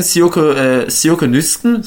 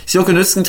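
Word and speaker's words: Sjoeke Nüsken: Siyoke Nüsken, so spricht man die ja aus, ja, so ein gerolltes O kann man sagen, wenn man das so ausspricht. Siyoke Nüsken trifft 25.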